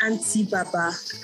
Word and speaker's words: Hantyy Barber: Yes, it's pronounced Auntie Baba.